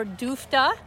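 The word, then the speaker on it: DFTA: Duluth Foot Trails Alliance, or DOOFTA, rhymes with oofta.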